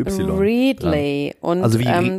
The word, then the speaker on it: Readly: Readly, ja.